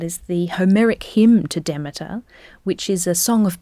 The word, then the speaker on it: Demeter: Yes, that's right, Demeter.